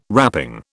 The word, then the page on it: wrapping: British English